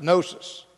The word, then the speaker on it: gnosis: The Gnostics of the second century, and actually in the late first century, the Gnostics were called Gnostics because the Greek word to know is the word gnosis, gnosis, but it's pronounced gnosis.